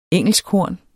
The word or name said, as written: engelskhorn